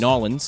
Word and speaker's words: New Orleans: In New Orleans, which I was told by a local is actually pronounced Nawlins, so I said it wrong already.